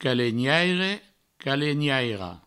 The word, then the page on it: calenhaire, calenhaira: calegnaire, calegnairo